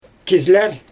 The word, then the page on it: kizlEr: Xyzyl